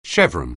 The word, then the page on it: chevron: English